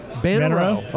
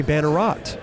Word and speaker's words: Bannerot: Bannerow. Okay. – Yeah, the T is silent. – The T is silent. Yeah. Simon Bannerow. Yeah. Everybody thought it was maybe Bannerot.